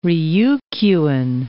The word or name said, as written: Ryukyuan